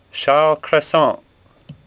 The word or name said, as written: Cressent